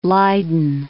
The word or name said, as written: Leyden